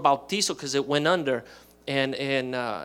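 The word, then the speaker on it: bautizo (βαπτίζω): And so the original Greek word where we get the word baptism from or baptize is bautizo. And the reason I say with a Spanish pronunciation is that's how you say it in Greek.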